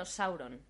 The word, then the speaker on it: Sauron: Es decir, que no sería Sauron, sino Sauron.